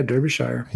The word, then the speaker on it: Derbyshire: Derbyshire. Is that how you say it? – That's how you say it. Yeah, Derbyshire.